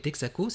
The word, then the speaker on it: Texaco: Et Texaco, c'est... alors je vais le prononcer à la manière martiniquaise, Texaco.